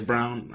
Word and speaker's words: Braun: And then coming off the bench, you know, Bruce Brown, and you also have the other Brown as well, whose name is actually spelled Braun, but says Brown. I don't know why it's pronounced Brown, but they have enough depth with those guys, Jeff Green, to be able to stay with whatever Miami's trying to do.